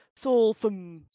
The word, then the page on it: Solphim: Phyrexian